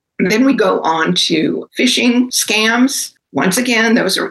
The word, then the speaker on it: phishing: Phishing, it's spelled P-H-I-S-H-I-N-G, but it's just like when you go to fish, F-I-S-H.